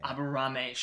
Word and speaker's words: Aburame: Aburame shows up to fight.